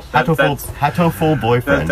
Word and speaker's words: Hatoful Boyfriend: I'm probably saying that wrong, but no. – Hatoful Boyfriend. – That's pretty much it.